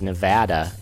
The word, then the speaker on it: Nevada: Oh, that is my guest telling me it's Nevada and that I will be killed for pronouncing it wrong.